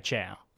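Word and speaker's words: Chao: I'm gonna a chow? Is that how you say it? A cow? – They're a baby. And yeah, chow.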